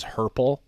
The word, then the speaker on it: herple: Do you want to know what the 2 archaic words are that rhyme with purple? – But of course. – One of them is herple. It's a British word which means to walk lamely or hobble. And then also we've got kerple. That's C-U-R-P-L-E, which is a Scottish word.